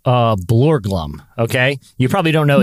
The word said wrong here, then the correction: Borglum: Are you sure you said that right? – I'm gonna spell it for you. Gutzon. That's his first name. – Gutzon. – Uh huh. And then Borglum. Borglum. – Yeah, Borglum. Yeah. – So anyway,